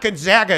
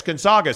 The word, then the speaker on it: Gonzaga: So the point is we went through this whole thing where people are like, it's not Gonzaga, it's Gonzaga.